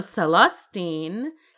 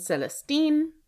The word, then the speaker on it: Celestine: My best friend, whose middle name is Celestine, pronounce it that way with the emphasis on the third syllable. There's also Celestine.